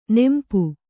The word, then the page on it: ನೋಂಪು: nōmpu